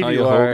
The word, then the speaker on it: Cuyahoga: I believe Cuyahoga.